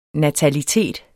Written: natalitet

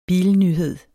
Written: bilnyhed

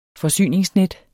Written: forsyningsnet

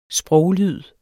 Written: sproglyd

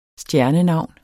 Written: stjernenavn

